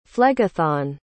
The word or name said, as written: Phlegethon